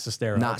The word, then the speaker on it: Sestero: You say Sestero, I say Sestero, I don't say that, but I just watched a video where that's how they say his name. – Oh, someone said it? – That's how you say his name. It was him saying it, yeah.